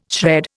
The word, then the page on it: shred: Indian